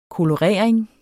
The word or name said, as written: kolorering